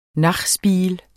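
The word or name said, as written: nachos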